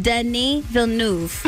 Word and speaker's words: Denis Villeneuve: How do you pronounce his name for real? – His name is pronounced Denis Villeneuve.